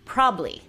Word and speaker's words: probably: Como se dieron cuenta, eliminé una sílaba entera al decir probably esto es muy común. Pero ya siendo muy correctos, la pronunciación es probably.